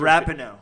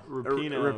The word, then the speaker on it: Rapinoe: What'd you just say? – I don't know how to say it. It's Megan. – I thought it was Rapinoe. – Rapinoe.